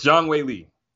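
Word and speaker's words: Zhang Weili: So it's Zhang Wei Li for now and forever.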